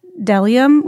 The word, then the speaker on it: bdellium: When you said typo, I thought you were talking about when it mentions Delium, which is spelled B-D-E-L-L-I-U-M, but you don't pronounce the B.